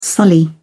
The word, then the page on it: sully: British English